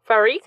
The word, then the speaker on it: Fariq: F-A-R-I-Q. – Farik. – Farik Hamid, the first officer, was flying the airplane.